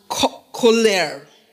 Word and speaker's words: colere: The word culture is from a Latin word that is pronounced colere. I hope I got it right. It's C-O-L-E-R-E, colere, which means to tend, which means to grow.